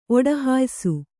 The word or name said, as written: ಒಡಹಾಯಿಸು